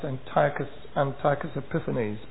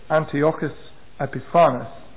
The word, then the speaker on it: Antiochus Epiphanes: There was a Syrian ruler called Antiochus Epiphanes, sometimes pronounced Antiochus Epiphanes, but probably more accurately Antiochus Epiphanes.